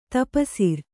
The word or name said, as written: ತಪಸಿರ್